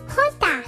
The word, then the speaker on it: jota: It's really neat because in English, the letter J normally has a J sound, like Jackie or Jason, but in Spanish, the letter J has a H sound, like the words juguetón or José. Well, my Jota familia amigos,